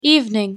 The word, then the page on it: evening: English